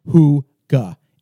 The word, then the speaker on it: hygge: And it says pronounced Huga, H-O-O-G-A, but it's spelled H-Y-G-G-E.